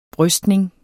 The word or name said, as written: brystning